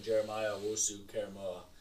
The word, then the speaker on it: Jeremiah Owusu-Koramoah: I also had them selecting Jeremiah Owusu-Koramoah.